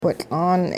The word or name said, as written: whitL'aan